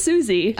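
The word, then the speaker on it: Siouxsie: It's just Susie.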